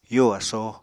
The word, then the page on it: yo asóo: apatani